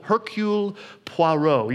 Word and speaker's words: Hercule Poirot: I practice pronouncing that because I don't know French. Hercule Poirot.